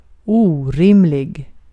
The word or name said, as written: orimlig